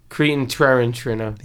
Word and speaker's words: Tarantino: Quentin Tarantino.